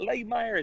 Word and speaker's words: Lahmeyer: Nobody knows how to say my last name or spell it. They're like, is it Lahmeyer? Is it Lemire?